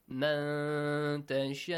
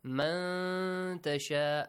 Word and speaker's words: مَن تَشَاءُ: وَتَرْزُقُ مَن تَشَاءُ بِغَيْرِ حِسَابٍ. Uh-huh. مَن تَشَاءُ. Nun Sakin, Ikhfa. Hide the sound of the Nun Sakin here. Man tasha', a nasal sound also.